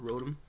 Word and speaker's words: Rotom: They have the, I don't know if you're familiar with the Pokemon called Rotom. – Nope. – Rhymes with Scrotum.